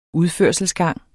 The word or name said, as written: udførselsgang